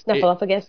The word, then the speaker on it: Snuffleupagus: Snuffleupagus? – Yeah, thank you.